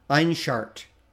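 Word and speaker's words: Ainshart: So it's A-I-N-S-H-A-R-T, okay? And if you, now pronounce that as one word. Einsheart is actually how people pronounce it.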